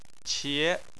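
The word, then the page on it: qie: tone 2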